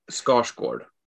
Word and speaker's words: Skarsgård: But it's the correct pronunciation is Skarsgård.